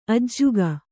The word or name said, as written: ಅಜ್ಜುಗ